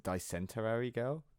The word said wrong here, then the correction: Dysentery Gary: It's called Dysentery Gary. Is what it's called.